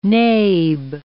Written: nabe

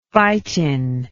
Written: phytin

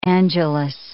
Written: Angelus

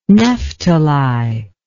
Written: nephtali

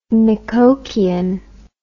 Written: Micoquian

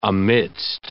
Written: amidst

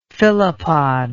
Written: phyllopod

